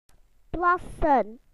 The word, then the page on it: to wee: British English